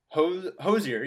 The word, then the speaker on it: Hozier: Hozier? – Hozier, yeah, that's right.